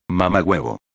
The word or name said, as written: mamahuevo